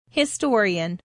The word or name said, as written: historian